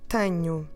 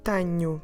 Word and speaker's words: tenho: So the first person, I have, we say eu tenho. Eu tenho, tenho, tenho, eu tenho. How do we spell it? T-E-N-H-O.